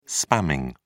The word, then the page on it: spamming: British English